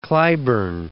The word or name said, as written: Cliburn